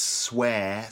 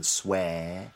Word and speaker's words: swear: And I should probably mention the fact that the pronunciation of the word is swear, so that's air, the air sound, swear.